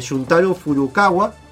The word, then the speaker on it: Shuntaro Furukawa: Shuntaro Furukawa.